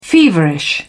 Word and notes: feverish: British English

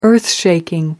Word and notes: earthshaking: British English

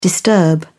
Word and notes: disturb: British English

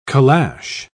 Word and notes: caleche: British English

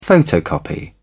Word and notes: photocopy: British English

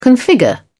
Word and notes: configure: British English

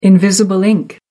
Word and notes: invisible ink: American English

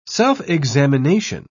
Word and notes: self-examination: British English

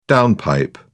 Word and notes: downpipe: British English

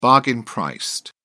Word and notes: bargain-priced: British English

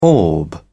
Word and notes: orb: British English

